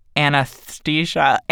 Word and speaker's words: anesthesia: I realized I had trouble pronouncing anesthesia, which I'm mispronouncing now because I want to have the hard T there, and I guess it's not a hard T.